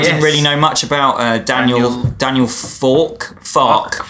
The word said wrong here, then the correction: Farke: But I think it's actually pronounced Farke. – Yeah, Farke.